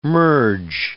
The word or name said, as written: merge